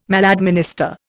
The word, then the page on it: maladminister: Indian